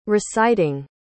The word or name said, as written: reciting